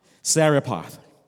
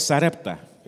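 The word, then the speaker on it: Zarephath: How do you say that in Georgia? Sarepath. Thank you. See, because where I come from is Sarepta. La viuda de Sarepta. But we're dealing with now the widow of Sarepath.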